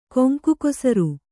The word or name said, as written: ಕೊಂಕುಕೊಸರು